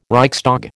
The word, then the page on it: Reichstag: American English